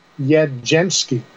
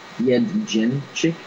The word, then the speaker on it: Jędrzejczyk: Jędrzejczyk. – Y-E-N-D. Yed. – Yen. There's no N in her last name. It's J-E-D-R. – Yed. Y-E-N-D is how you pronounce it.